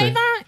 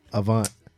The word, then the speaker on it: Avant: It ain't Avon. – Nice Avant. – What is it? – Avant. You too smart for that.